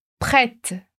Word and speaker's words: prête: Prête. Repara que esse T do final, ele é meio assopradinho. Então, vamos lá mais uma vez. Prête.